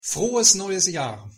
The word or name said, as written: frohes neues jahr